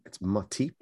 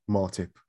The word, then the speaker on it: Matip: I think, I mean, the good thing is that in Joel Matip, and by the way, Ola White, if you're listening, it's Matip, not Martip.